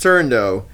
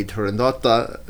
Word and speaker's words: Turandot: Puccini's granddaughter says it's Turandot. Because it's a truncated version of what in Italian would be Turandotta, and whatever.